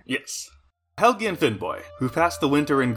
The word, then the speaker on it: Finnbogi: This ship was commanded by two brothers, Helgi and Finnboy. – Finnboy? – It is spelled Finnbogi, but I have been told that you're supposed to pronounce the G in that like a Y.